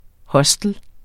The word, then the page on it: hostel: [ ˈhʌsdəl ]